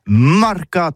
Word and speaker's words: mercat: Eh bien, disons à tous, mais le mercat de l'Uni ou le mercat des rebelles, mais en gascogne toulousano, c'est prononcé sur les marcat, avec un A marcat. En gascogne toulousaine, bah, du côté par exemple de l'île des Baches, c'est l'île Jourdain, pour parler du marché, vous prononcez les marcat.